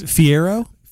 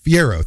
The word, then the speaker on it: Fiero: Fiera. – Fiero. – Fiero.